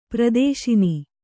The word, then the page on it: ಪ್ರದೇಶನ: pradēśana